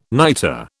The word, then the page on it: nitre: British English